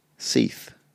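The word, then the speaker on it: seiðr: So Freya was from Norse mythology, associated with love, sexuality, beauty, fertility, gold, war, death, and a type of sorcery called, I'm going to pronounce this as best I can, Seidr, which is often anglicized to be pronounced Seath.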